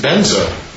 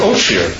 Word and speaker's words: Vajra: Vajra, in Sanskrit, they pronounce benza. When it goes from Tibet to Mongolia, the Mongolians pronounce it oshir.